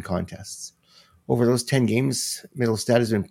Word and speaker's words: Mittelstadt: All right, our next guy on the list here is from the Buffalo Sabres, Casey Mittelstadt. I always pronounced it Mittelstadt, not Mittelstadt. Oops.